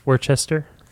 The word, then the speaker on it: Worcester: Worcester? – Worcester.